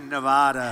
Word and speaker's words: Nevada: I said when I came out here, I said, nobody says it the other way. It has to be Nevada.